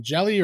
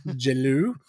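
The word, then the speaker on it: Jellÿ: Track 2, Three Little Pigs by Green Jelly or Jello, or depending on how you say it. – Jello.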